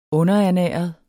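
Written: UNDERERNÆREDE

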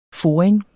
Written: foring

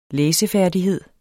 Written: læsefærdighed

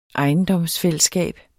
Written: ejendomsfællesskab

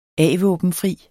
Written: a-våbenfri

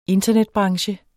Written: internetbranche

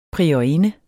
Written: priorinde

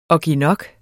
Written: ogginok

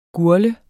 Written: GURGL

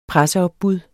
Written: presseopbud

